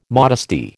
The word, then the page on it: modesty: American English